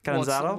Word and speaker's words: Catanzaro: Catanzaro.